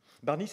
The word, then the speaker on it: Barni: Et Jules Barny, Barny, c'est un Jules, comme tous les la République des Jules, ça s'écrit B-A-R-N-I.